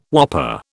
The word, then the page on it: whopper: British English